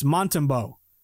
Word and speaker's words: Montembeault: It's Montembeau. I kept saying Montembeault as I'm trying to correctly pronounce my French-Canadian, but Montembeau.